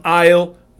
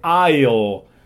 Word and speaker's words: aisle: El pasillo, cuando se habla de lugares de supermercados o tiendas, se dice aisle. Yo sé que se escribe con S, pero no se pronuncia. Solo se pronuncia aisle. Aisle.